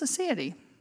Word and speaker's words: aseity: I mean, I looked at it, I thought it was aseity, but it's aseity, if that woman that said it correctly.